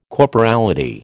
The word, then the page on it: corporality: American English